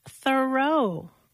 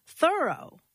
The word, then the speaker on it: Thoreau: All this time, I thought his name was pronounced Thoreau, but no. – How is it pronounced then? – Thoreau. – Like, thoroughly did it. – Yeah, or I'm thoroughly surprised that it's pronounced that way.